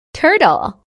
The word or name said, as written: turtle